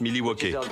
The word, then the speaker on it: Milwaukee: Ça se prononce Milwaukee.